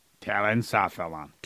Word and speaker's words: telencephalon: Telencephalon. – Telen or talon? – Talon. – Spell it. – T-E-L-E-N. C-E-P-H-A-L-O-N.